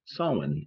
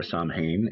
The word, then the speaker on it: Samhain: we are going to be talking about Samhain, or Samhain, as some people pronounce the band, depending on whether you go with the traditional Celtic pronunciation or the Americanized version.